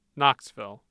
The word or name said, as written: Knoxville